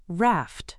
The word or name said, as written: raft